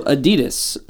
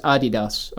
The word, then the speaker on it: Adidas: I don't remember what it is, but it's pronounced Adidas, apparently, like in reality. – Right. I know I'm calling it Adidas.